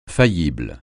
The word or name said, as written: faillible